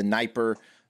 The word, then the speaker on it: Dnieper: So this is attributed to Kiev's geography, where the high bank of the Dnieper River, I believe it's a silent D there, rises above the rest of the city.